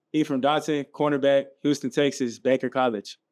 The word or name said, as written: Ephraim Dotson